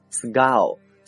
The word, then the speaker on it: skull: 比如说头盖骨叫做 skull, skull, S-K-U-L-L。 OK, 这个词啊注意它的浊化现象， SK 呢我们读成歌的感觉， skull, skull, 名词头盖骨或者是颅骨这样的含义。